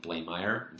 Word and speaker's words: Blaemire: much like a lot of people have trouble pronouncing my last name, Blaimire, it looks like, you know, I get like Blaimire, Blarmar,